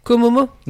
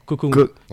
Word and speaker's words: Kokomo: Kokomo, ça s'écrit K-O-M-O, enfin K-O espace M-O espace M-O. – Komomo? – Non, Kokomo. K-O-K-O.